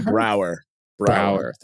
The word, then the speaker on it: Braugher: Vance, Andre Brauner. Wait, how do you say his last name? – Andre Brauer.